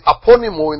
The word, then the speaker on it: aponemo: That word there is aponymo in the Greek. A-P-O-N-E-M-O.